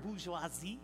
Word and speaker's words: bourgeoisie: And it's interesting because last week I talked to you guys about the term bourgeoisie. You guys laugh when I say that, but it's a French term, right? Right, Nico? Bourgeoisie. Am I saying it right? Yes, thank you.